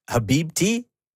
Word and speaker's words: Habibti: Iceman, of course, came with two more albums, Maid of Honor and Habibti. Am I saying that right? – Yeah.